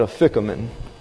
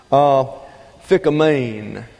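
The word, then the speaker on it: aphikomen: They take the P-H sound here, and they make it that an F, and they put the accent here and pronounce it a ficamen. But it's a Greek word, as you obviously can see, and the accent is on the omicron. So it's phikamein. The accent is on the last syllable.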